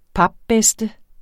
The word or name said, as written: papbedste